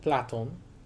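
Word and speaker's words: Platon (Πλάτων): Now, Plato, which was probably pronounced more like Platon, derived from platis, which meant broad or wide.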